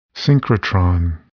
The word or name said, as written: synchrotron